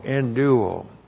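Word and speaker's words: enduo (ἐνδύω): E-N-D-U long O, enduo.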